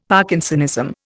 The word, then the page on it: Parkinsonism: Indian